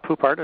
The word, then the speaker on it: Poupart: Yeah, my name is Ralph Poupart. It's kind of an unusual last name. It's French-Canadian. It's actually spelled P-O-U-P-A-R-T.